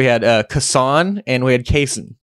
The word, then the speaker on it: Cason: But it is Cason. Someone on Twitter put it like this. Just think of Mason, but with a C.